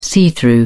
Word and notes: see-through: British English

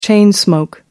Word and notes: chain smoke: American English

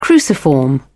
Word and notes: cruciform: British English

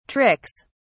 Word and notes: tricks: British English

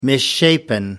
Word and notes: misshapen: American English

